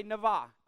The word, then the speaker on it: navah: Everybody say navah. It's N-A-V-A is how you spell it, but you pronounce it N-A-V-A is how you pronounce it.